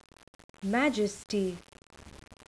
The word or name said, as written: majesty